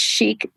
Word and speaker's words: Chic: So it's Cheek, C-H-I-C, and then we spell awareness, A-W-E-A-R-N-E-S-S.